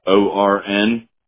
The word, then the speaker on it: Ornowski: ORNOWSKI. 3 words, or, now, and ski. – Gotcha. – And that's how it's pronounced. Ornowski.